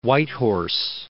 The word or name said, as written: Whitehorse